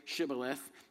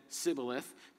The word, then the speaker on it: Shibboleth: And when he said, no, they said to him, say Shibboleth. And he said, Sibboleth, for he could not pronounce it right.